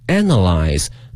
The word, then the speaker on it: analyze: To collect and 分析这个词有一定难度哈，它的发音是 analyze, 拼写是 A-N-A-L-Y-Z-E,